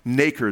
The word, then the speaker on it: nacre: It secretes an element called nacre, N-A-K-E-R, N-A-C-R-E, I think, actually. That's how you spell it. Nacre, though, it's pronounced nacre,